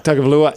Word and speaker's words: Tagovailoa: Tagovailoa, yeah. – Tagovailoa.